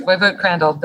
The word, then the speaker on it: Woywod Crandle: Woywod Crandle.